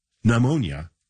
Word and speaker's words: pneumonia: So the N in him, the T in listen, the G in sign, and the P in pneumonia, all silent.